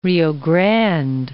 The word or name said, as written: Grande, Rio